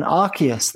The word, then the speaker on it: Arceus: But no, and the other one is there's a game called Pokemon Arcanus. I'm never going to say it right. Pokemon Arceus. There you go.